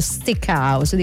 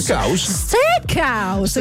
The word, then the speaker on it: steakhouse: loro ti tipo stick house, dico, che ne so, chiedi scusi, sorry, where is the stick house? Loro ti guardano, stick house. Steakhouse, capito? Ti ricordano bene come si pronuncia, così ti svergognano subito.